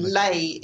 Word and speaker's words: Leh: Yes, Ladakh is the area, and Leh is the town. – Okay. – The picture on the left, it's got Leh there.